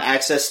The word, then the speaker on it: AXS: Access? – Might be Access. I don't know. – That's how it's pronounced. – Okay. On Access TV, joining Josh Barnett.